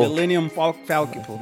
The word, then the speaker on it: Millennium Falcon: Is it okay if I take the Manelian Falcon, and I know I said that wrong, can I take the Falcon and travel? – Millennium Falcon.